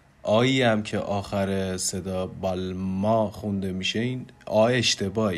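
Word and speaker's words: Balmain: ما در زبان انگلیسی خیلی جاها میشنویم که اون رو بالمین تلفظش میکنن و دقیقا همونطوری که نوشته شده میخوننش، ولی در زبان فرانسه فونتیک های آخرین کلمه عضوی از آواهای نزلیزه یا تو دماغی هستن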